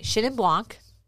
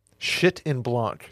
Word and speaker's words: Chenin Blanc: Chenin Blanc. – Shit in Blanc. – Chenin. – Got it. Chenin Blanc. – It's not shit in Blanc.